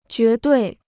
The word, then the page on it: 绝对: jué duì